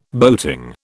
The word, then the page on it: boating: British English